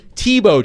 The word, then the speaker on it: Thibault: So I own a 2003 NHL All-Star Jocelyn Thibault jersey, and that's not spelled like Tim Tebow. It's spelled T-H-I-B-A-U-L-T. – T-H-I.